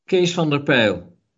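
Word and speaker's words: Kees van der Pijl: Kees van der Peil.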